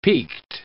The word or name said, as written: peaked